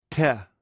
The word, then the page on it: te: greek